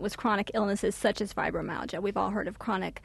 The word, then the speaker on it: fibromyalgia: You've got a story about fibromyalgia, am I pronouncing it properly? – Yes, yes.